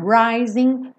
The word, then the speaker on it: rising: Rising frequentemente, 99,99% das vezes. Um G numa palavra com ING não é pronunciada. São pouquíssimos sotaques no mundo que pronunciam esse G. Então, rising, rising of the sun.